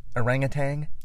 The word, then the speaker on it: orangutan: Orangutan? – Yeah. – With a G at the end? No. It's orangutan.